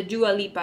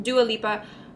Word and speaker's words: Dua Lipa: So, the first ensemble that I want to actually talk about is the Dua Lipa. Am I saying that right? – Mm-hmm. – Dua Lipa.